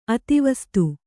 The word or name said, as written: ಅಧಿವಾಸಿತ